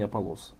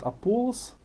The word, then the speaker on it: Аполлос: Кстати, об имени Аполлос. Иногда братья говорят Аполлос, но правильно говорить Аполлос. Ударение на второе О, на третий слог.